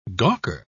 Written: gawker

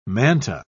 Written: manta

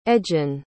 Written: edgan

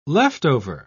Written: leftover

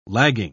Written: lagging

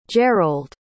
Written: jerold

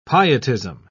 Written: pietism

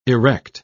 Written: erect

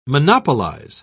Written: monopolize

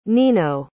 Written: nino

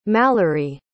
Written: mallorie

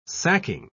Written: sacking

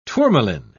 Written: tourmaline

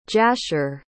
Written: jasher

